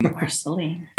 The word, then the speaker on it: Marceline: Marceline, Missouri. I'm sure I mispronounced that. – Marceline, yes.